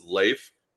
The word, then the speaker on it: Leif: But yes, technically 100%, it is Leif, L-A-Y-F, as far as pronunciation goes.